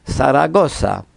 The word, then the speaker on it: Zaragoza: La nomo de la ĉefurbo de Aragonio estas origine Zaragoza. Hispanoj prononcas la konsonanton zo kvazaŭ so. Latinamerikanoj simpligas ĝian fonetikon kaj zo ili prononcas klare kiel so. Zaragoza.